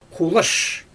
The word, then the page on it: xulaʃ: Xyzyl